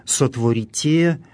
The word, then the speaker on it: сотворите: Здесь глаголы «сохраните», «сотворите», в которых мы слышим специфическое церковнославянское ударение, встречающееся именно в этих грамматических формах, второе лицо множественного числа настоящего времени, ударение на окончании.